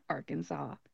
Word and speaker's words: Arkansas: why these two states are not pronounced the same way. Our teacher didn't really have an answer, and I don't really have an answer for you either, but that is how they are pronounced, Kansas and Arkansas.